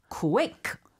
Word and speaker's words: quick: quick, 그죠? – 한국에서는 이제 cooey라기보다는 자꾸 퀴퀴퀴 이런 발음이 나오거든요. – 네. – 그래서 천천히 KW sound, quick.